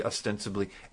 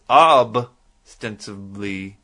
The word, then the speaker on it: ostensibly: And by the way, my wife told me that I was pronouncing ostensibly wrong because I've been pronouncing it for years as ostensibly. Ostensibly is what she says. That I should say.